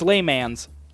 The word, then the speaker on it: Le Mans: It's Le Mans. Le Mans.